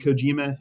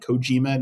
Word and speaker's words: Kojima: You know, I'm sure that Dennis Villeneuve or whatever is kicking themselves that Hideo Kojima, there's a wrestler named Satoshi Kojima in New Japan Pro Wrestling. I think we pronounce the name Kojima, not Kojima. So I go back and forth between the pronunciations.